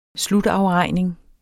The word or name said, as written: slutafregning